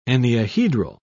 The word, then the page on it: enneahedral: British English